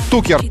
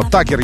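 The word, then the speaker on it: Tucker: Или Тукер пишется. Но Такер, я думаю, ее зовут.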